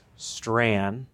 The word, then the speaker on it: Strachan: It's a Scottish last name with a Gaelic pronunciation. That's the first thing to say. – Okay. – Because everybody. – It looks like Strachan. – It looks like Strachan or Strachan or Strahan. It's Strand.